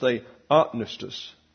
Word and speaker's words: theopneustos: But you'll note that the second syllable is what's accented here. We like to say theopneustos. It's theopneustos. And so that sounds like maybe you mispronounced it, but that's the way you do it.